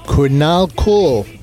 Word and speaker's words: Kurnal Cool: Cornal Cool. I'll spell it for you. K-U-R-N-A-L-C-O-O-L. Cornal Cool. It's like Colonel Cool.